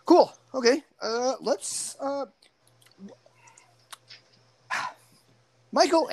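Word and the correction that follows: Michel: Ansel. – Michelle Ansel. Michelle. See, you said this, and I'm stupid. Michelle Ansel. – You're not stupid. You're just pronouncing it the way it looks like it should be pronounced.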